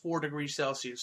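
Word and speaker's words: 4°C: And then Studios, so I believe it's supposed to be pronounced 4 Degrees Celsius Studios, which is a Japanese animation studio.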